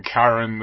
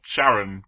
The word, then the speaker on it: Charon: That's a slight difference, isn't it, between Charon, the guy who's taking you to Hades, or Charon, my wife. – Yeah, which is why we have the two pronunciations.